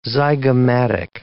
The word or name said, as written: zygomatic